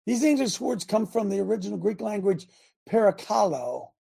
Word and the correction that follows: parakaleo: I think I didn't say it right. It's a compound word comprised of the preposition para and the verb kaleo. Para meaning to come alongside, while kaleo means to call. Together, parakaleo is an emphatic verb in the first person singular that means to call alongside and urge.